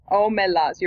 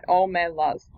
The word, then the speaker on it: Omelas: Le Guin, called The Ones Who Walk Away from Amelas. Amelas. Amelas. I think I wrote how it was meant to be pronounced. Hang on. How did I write it? Omelas. You're right. Omelas.